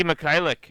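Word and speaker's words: Mykhailiuk: Hornets receive Mikhailik. I'm not even going to try and pronounce his name. 2nd-round pick. – Svi Mikhailik.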